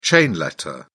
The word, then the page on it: chain letter: British English